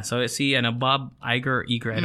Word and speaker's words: Iger: I-G-E-R. Iger. I'm guessing Iger. Iger. – Mm-mm. – Confirmed. – Pa siya may ID ng Instagram. – Iger.